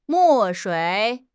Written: 墨水

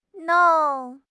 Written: nong4

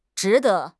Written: zhi2 de5

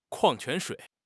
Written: kuang4 quan2 shui3

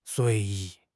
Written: 随意

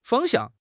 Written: fang1 xiang4

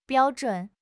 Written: biao1 zhun3

